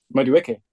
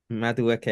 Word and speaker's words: Madueke: Madueke. – Madueke.